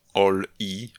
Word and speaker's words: Oli: On się nazywa Oli, O-L-I, ale czyta się jak All-I, czyli wszystko w elektryce.